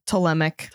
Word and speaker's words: Ptolemaic: The first one, the Ptolemaic. How do you say it's Ptolemy? – Yeah. Ptolemaic dynasty.